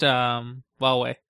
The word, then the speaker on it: Huawei: I think that's pronounced Huawei. – Huawei. – Yeah. I like Huawei better, personally, but apparently... – Is it actually how... – Apparently, that is what okay. – official sources pronounce it as.